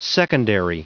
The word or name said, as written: secondary